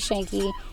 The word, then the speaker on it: Schuenke: My last name is not like it's spelled. It's Penny Schuenke.